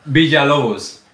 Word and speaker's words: Villalobos: Vijay Lobos.